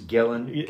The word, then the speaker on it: Gillen: how do you pronounce his last name? Gillian's. – Gillen. – Kieran Gillen. I have no idea. That's how I pronounce it. – Okay. No, yeah. Sorry, I thought it was Gillian, but you're right. It is Gillen.